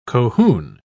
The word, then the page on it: cohune: British English